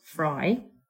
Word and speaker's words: Frey: He's called Casey Fry, and his name is actually spelled Casey, C-A-S-E-Y, and then F-R-E-Y, but it's pronounced Fry.